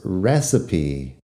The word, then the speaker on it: recipe: Recipe, R-E-C-I-P-E, e. recipe, también es un poco extraño que esta última sílaba con la E al final se pronuncie como sílaba extra, porque normalmente una E al final así sería silenciosa, muda, pero recipe es la receta de, de cocina.